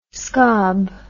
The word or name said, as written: scob